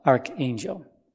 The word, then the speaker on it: archangel: A-R-C-H is pronounced like a K, arch, when it comes before a vowel, archangel. But when A-R-C-H comes before a consonant, as an archbishop, then it's pronounced A-R-C-H, arch.